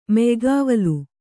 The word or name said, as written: ಮೆಯ್ಗಾವಲು